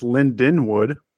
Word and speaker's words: Lindenwood: This is going to be Linwood in mid-September of 2027. What do you know about Linwood? – I know that they're pronounced Lindenwood. – Oh, yeah, sorry. My bad.